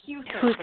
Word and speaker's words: Huther: Huther? Am I pronouncing it? It's Huther.